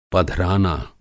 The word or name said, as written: पधराना